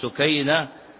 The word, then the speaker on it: سكينة: ومن العجب ومن اللطائف أن الذي حقق كتاب تعرف من مو راجل، ما هو رجال، الذي حقق كتاب امرأة شامية، وهي سكينة الشهابي، سكينة بالتصغير الشهابي.